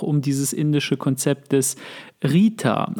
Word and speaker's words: ṛta: Also das ist, das schreibt sich RTA und das R hat unten so einen Punkt. Sieht ganz komisch aus, ist eine Sanskrit-Schreibweise und äh, wird halt Rita, wie der Name Rita ausgesprochen.